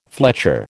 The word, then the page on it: fletcher: American English